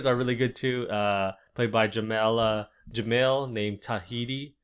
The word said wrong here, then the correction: Tahani: Tahani. That's how you say her name.